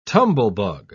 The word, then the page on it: tumblebug: British English